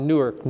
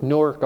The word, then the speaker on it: Newark: We pronounced that Newark, Ohio. Remember that? Not Newark, Newark, Ohio.